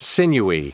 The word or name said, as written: sinewy